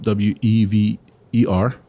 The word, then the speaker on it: Wever: And, um, this particular podcast is going to talk about um, a particular gentleman, a gentleman named Ernest Glen Wever or Wever. That's how you spell it. W E V E R.